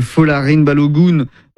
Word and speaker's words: Folarin Balogun: Donald Trump qui appelle la FIFA pour lever un carton rouge qui visait le meilleur buteur de l'équipe américaine, Folarin Balogun.